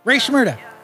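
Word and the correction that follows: Rae Sremmurd: That's wrong. It's Ray Shrimmered. – Well, that was close it's enough. – It's ear drummers backwards.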